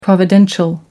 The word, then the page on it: providential: American English